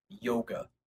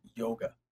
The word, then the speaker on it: yoga: We normally call it the word yoga. Yoga, kind of like yogurt, but with a ga on the end of it, yoga. So that is actually not the correct way to pronounce this word. It's actually pronounced yoga.